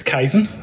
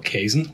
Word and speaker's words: Kaizen: So it's Kaizen. – Kaizen, yeah. – Or as I used to call it, Kaizen, which is incredibly wrong.